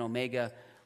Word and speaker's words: omega: And then omega, which is more of the long O.